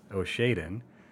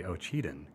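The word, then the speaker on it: Ocheyedan: And for many years, I've pronounced this city as Oshaden, and I was corrected. It is actually Ocheden.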